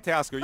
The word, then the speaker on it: Teoscar: I said his name wrong, didn't I? Teoscar. – Oh, Teoscar. You're good. You're good.